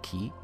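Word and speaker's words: Key (χ): We then have Kingdom Hearts Key, which stylized as, you know, X, usually people see it as X, but it's actually pronounced Key in the game, which is a cinematic again, which probably leads to Kingdom Hearts 3 as well.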